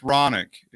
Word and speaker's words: Ronick: The name is pronounced Ronick. It rhymes with tonic.